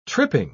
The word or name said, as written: tripping